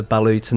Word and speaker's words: balut: So this is balut. – Yep, so I had that balut. – Did you? Yep. Balut is how you say it.